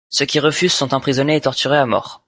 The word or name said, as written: refusent